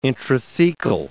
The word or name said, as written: intrathecal